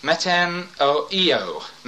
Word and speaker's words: metanoeo: M E T A N O E O. Long O at the end. Metanoeo.